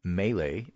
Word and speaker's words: Mele: The opponent for the Republicans who had an uncontested primary is a guy named Greg, G-R-E-G-G, two Gs, okay. Greg Mele, M-E-L-E, rhymes with Pele, I guess, who is a libertarian, occasionally Republican, corporate lawyer from Wall Street.